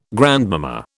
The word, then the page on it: grandmama: British English